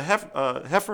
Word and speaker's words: heifer: For if the blood of bulls and goats and ashes of the heifer, am I saying that right? – Yes.